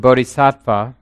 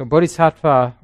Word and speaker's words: bodhisattva: He says, "It is said that in the course of his long training for enlightenment over many lives, the bodhisattva, or the bodhisatta, can break all the moral precepts." So bodhisattva or bodhisatta, two ways to pronounce the word, that's the somebody on the way to being a Buddha.